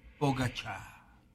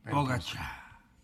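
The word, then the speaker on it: Pogačar: La pronunciación correcta de Pogacar es Pogacar. – ¿Sí? – Pogacar.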